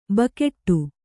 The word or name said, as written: ಬೇಗಡೆ